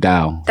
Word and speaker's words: Dao: Yeah, you're like but he has this amazing book that called is it Tao or Dao? – It's more of a deep pronunciation. Dao.